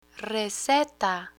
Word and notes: receta: Spanish

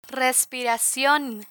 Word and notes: respiracion: spanish

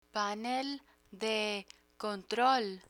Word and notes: panel de control: Spanish

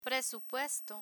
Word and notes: presupuesto: Spanish

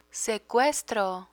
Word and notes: secuestro: spanish

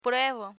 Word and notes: pruebo: Spanish

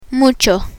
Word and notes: mucho: Spanish

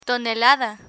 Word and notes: tonelada: spanish

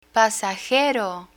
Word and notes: pasajero: Spanish

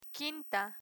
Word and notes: quinta: spanish

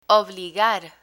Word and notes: obligar: Spanish

